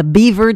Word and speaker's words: Biewer: The American Kennel Club calls it the Beaver Terrier, though it's spelled B-I-E-W-E-R.